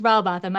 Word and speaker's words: Rowbotham: Is it Rowbotham? How do you say his name? – I think it's Rowbotham. I've consulted a number of British people, and that seems to be the consensus.